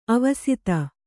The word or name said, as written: ಆವಸಿತ